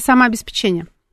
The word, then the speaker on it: самообеспечение: Это самообеспечение. Самообеспечение. Правильное ударение надо ставить. Мы же на радио.